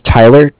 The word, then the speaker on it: Tyler: My name is Tyler, T-Y-L-E-R, and I'm calling from Cardiff. It's spelled C-A-R-D-I-F-F, as in Frank, Frank.